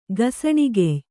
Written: ಗಸಣಿಗೆಯ್

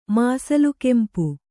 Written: ಮಾಸಲುಕೆಂಪು

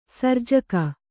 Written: ಸರ್ಜಕ

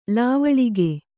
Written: ಲವಳಿಗೆ

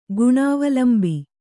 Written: ಗುಣಾವಲಂಬಿ

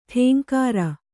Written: ಟೇಂಕಾರ

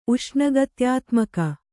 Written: ಉಷ್ಣಗತ್ಯಾತ್ಮಕ

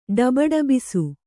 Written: ಡಬಡಬಿಸು

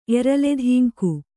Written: ಎರಲೆದಿಂಕು

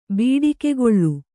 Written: ಬಡಿಗೆಗೋಲು

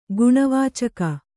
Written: ಗುಣವಾಚಕ

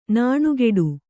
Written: ನೊಣಗೇಡಿ

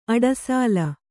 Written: ಅಡಸಲ

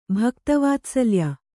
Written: ಭಕ್ತವಾತ್ಸಲ್ಯ

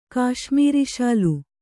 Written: ಕಾಶ್ಮೀರಿಶಾಲು

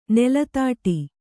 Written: ನೆಲತಾಟಿ

